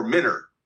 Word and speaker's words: Mentor: It's spelled Mentor, but the people who live there call that Mentor or Minter.